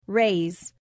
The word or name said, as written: raise